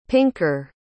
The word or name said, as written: pinker